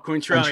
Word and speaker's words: Cointreau: Or Cointreau, am I pronouncing that correctly? – Cointreau, yeah.